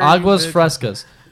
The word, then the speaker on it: Aguas Frescas: Aguas Frescas.